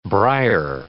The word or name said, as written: brier